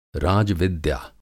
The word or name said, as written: राजविद्या